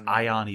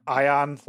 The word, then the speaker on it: Ion: They pronounce it as Ion, even though it's spelled very strangely in the localization. – It's okay. I'll remember. Ion, like Ion Storm.